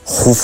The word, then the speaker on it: Keops: se escribe Keops, pero se pronuncia Jufu,